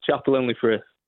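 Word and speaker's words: Chapel-en-le-Frith: So it's called, it's Chapelle-en-le-Frith.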